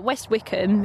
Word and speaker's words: West Wycombe: Yeah, because West Wycombe is actually how you say it.